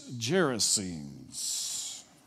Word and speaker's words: Gerasenes: and he'll say, this word is gerasenes, like that. So I wanted to say it just like he does, gerasenes.